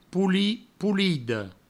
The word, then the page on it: polit, polida: poulit, poulido